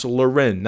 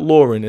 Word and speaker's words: Loren: It's pronounced Loren, not Lauren. It's like that, that polo pronunciation on them, right?